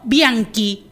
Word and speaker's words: Bianchi: No, Bianchi, con Q. Porque aprendí en fonética del italiano que CH en italiano se pronuncia como Q, digamos.